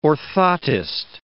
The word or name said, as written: orthotist